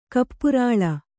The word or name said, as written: ಕಪ್ಪುರಾಳ